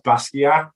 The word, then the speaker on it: Basquiat: It's Jean-Michel Basquiat, I think. – It's pronounced Basquiat. Haitian French, for the record.